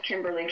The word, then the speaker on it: Kimberly: My name is spelled Kimberly, K-I-M-B-E-R-L-Y, and my last name is Brouillette, B-R-O-U-I-L-L-E-T-T-E.